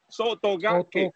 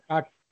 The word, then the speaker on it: Sotogake: Terma Sotogake realmente es ya finalmente, un japonés me dijo cómo que se pronuncia. Y ya hay que pronunciarlo en sílaba. Sotogake. – Sotogake.